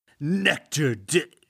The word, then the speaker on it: Nectar D'Or: And how do we pronounce it again? – Nectar D'Or.